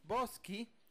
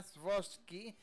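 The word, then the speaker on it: Chbosky: La dirige Steven Shbosky, porque así se pronuncia, Shbosky, lo busqué en Google.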